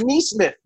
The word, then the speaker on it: Nesmith: Good job on that pronunciation. – Yeah, right. Thank you. Shout out to Ryan Brennan, our Sixers producer, because I was saying Naismith. That's what it looks like to me. But it is Nesmith.